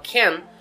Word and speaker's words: can: Soletrando o can, temos a ideia de C-A-N, ou C-A-N, mas na pronúncia, esse A soa mais próximo de um E, como can e não can. Mas é possível que, dependendo de alguns sotaques e regiões, você escute como can.